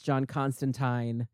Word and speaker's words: John Constantine: By the way, British listeners, I am aware that it's pronounced John Constantine. No one in America says that, and you just have to deal with it because I will never remember unless I think about it.